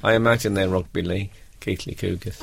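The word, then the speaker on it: Keighley: It's pronounced Keith Lee Cougars, not Keely Cougars.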